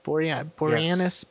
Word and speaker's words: Boreanaz: David Boreanaz. – Boreanaz.